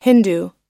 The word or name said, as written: hindu